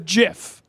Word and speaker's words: GIF: He sent me the obituary in the New York Times of the man who created the GIF, who said, and I quote, "Webster's Dictionary allows both pronunciations, but they're wrong. It's a GIF."